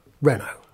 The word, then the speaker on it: Renault: Can you not have somebody, when they're doing the voice recording, in there to say, Mr. Douglas, no, actually you pronounce it Renault. – Yeah, it's extraordinary, isn't Renault.